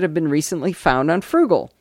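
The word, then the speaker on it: Froogle: The next Google tool on the list is Frugal, which is an oldie but a goodie.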